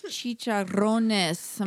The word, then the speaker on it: chicharrones: How do you say it? – Chicharrones. I'm going to just roll my R's on the chicharron there.